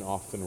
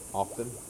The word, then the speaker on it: often: I got shit for saying often wrong, so I always... – How do you say it? – Often. – That's what I do now, too, but I used to say often.